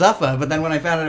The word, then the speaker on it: Zuffa: Is it pronounced Zuffa? – Yeah, it sounds like an energy drink.